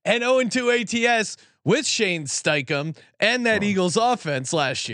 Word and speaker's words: Steichen: No, you said it wrong. – I'm saying like Steichen. – Steichen. There's an E in there.